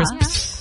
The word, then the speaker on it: crisps: All right, that's a word that I really can't say. Crisps. – Crisps. – Crisps. – Is that I say it right? Crisps. – Yeah. – Crisps. – Crisps. It sounds a little bit like you're calling a cat.